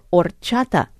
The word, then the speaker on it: horchata: Under H for horchata, the H in Spanish is silent, a ladle of the sweet drink is being poured into a glass, fanning itself under a smiling sun.